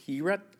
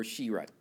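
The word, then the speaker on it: herut (חברות): So the Hebrew word for fellowship is herut or shirut, and it's spelled this way, C-H-A-V-E-R-U-T. It's pronounced herut or shirut.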